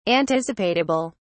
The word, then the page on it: anticipatable: British English